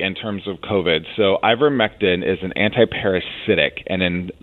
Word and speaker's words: ivermectin: Which is not recommended by, I don't know how to say, how do you say it? – Yep, ivermectin, yep.